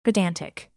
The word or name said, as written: pedantic